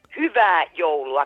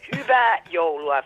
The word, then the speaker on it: hyvää joulua: Si dice hyvää joulua con HYY, hyvää, me lo puoi ripetere? – Guarda, mi fido della tua pronuncia. – Ok, hyvää joulua Federico.